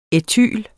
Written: ætylen

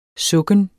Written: sukken

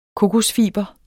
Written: kokosfiber

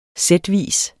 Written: sætvis

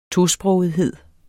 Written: tosprogethed

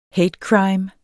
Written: hate crime

